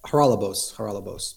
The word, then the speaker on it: Haralabos: Haralabos, yes.